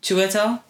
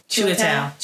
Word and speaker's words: Chiwetel: Chiwetel? – Yeah. – Chiwetel.